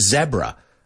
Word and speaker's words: zebra: I believe the Brits say it's a zebra crossing, and I believe they pronounce it zebra, a zebra crossing.